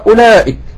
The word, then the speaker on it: أولئك: سأريكم دار الفاسقين، سأريكم سأريكم، نقرأ أولئك، وهذه الواو لا تنطق سايلنت يعني.